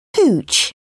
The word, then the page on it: hooch: British English